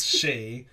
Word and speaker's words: Sidhe: I think you say it like C. – C. – I'm not entirely sure. – Don't email in. – She. Like, part of, like, Banshee. – Yeah, she. Okay. She.